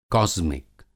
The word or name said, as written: कास्मिक